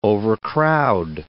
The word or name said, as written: overcrowd